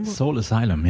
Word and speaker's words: Soul Asylum: Soul Asylum, ja. – So wird so es ausgesprochen.